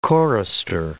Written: chorister

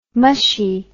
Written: mushie

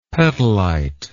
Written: petalite

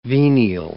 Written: venial

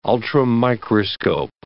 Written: ultramicroscope

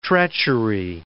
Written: treachery

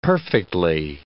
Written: perfectly

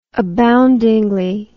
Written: aboundingly

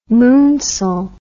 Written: moonsail

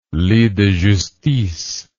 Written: lit de justice

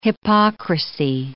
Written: hypocrisy

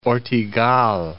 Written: Ortegal, Cape

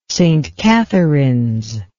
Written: St Catharines

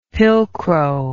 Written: pilcrow

